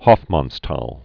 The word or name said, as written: Hofmannsthal